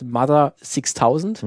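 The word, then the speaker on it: MU-TH-UR 6000: Äh, die heißt Mother 6000. Mhm. Ähm, also auch wie, also Mutur geschrieben, aber es wird dann als Mother auch ausgesprochen und mhm.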